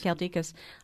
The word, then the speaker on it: Galdikas: I'm interested that Louis Leakey chose Diane Fossey as well, and I'm going to say this wrong, but Brute Galdikas. – Galdikas.